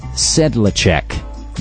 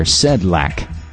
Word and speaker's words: Sedlacek: Originally from the Bellevue News-Democrat, Terry Sedlacek, and I've heard it pronounced some different ways, Sedlacek or Sedlack, S-E-D-L-A-C-E-K.